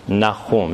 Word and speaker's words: Nahum: Nahum, that's how you pronounce that.